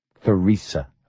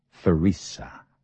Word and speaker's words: Theresa: The name was Theresa Lee, with the T and the H pronounced together like the or theme or therapy. Theresa.